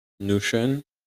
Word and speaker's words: Notion: Donc j'utilise pour ça un logiciel un logiciel qui s'appelle Notion, N-O-T-I-O-N. là ah, c'est mon accent, Je suis obligé de vous expliquer comment ça s'écrit parce que l'accent anglais Peut-être que vous ne l'avez pas tous, tu vois, Notion, vous l'auriez écrit avec un CH, des trucs comme ça.